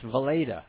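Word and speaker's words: Weleda: It's called Walita. It's from Germany. It's been around 100 years, and it's not commercial. – Good. That's pronounced Valeda.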